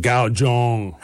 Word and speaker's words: guzheng: G-U-Z-H-E-N-G, or some Cantonese pronounce it, gao zhong.